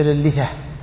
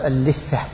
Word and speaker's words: اللثة: قل ولا نيئه، هكذا تنطق، ولا نيئه بمطبوخه، مثل اللثة، لا تقول اللثة، قل اللثة بالتخفيف.